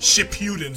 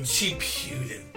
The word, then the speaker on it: Shippuden: Shippuden. – Shippuden.